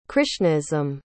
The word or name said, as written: krishnaism